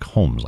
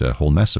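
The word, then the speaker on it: Holmès: Looks like Holmes, like Sherlock Holmes, but she has the accent over the E and pronounced it Holmes, apparently.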